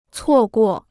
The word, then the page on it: 错过: cuò guò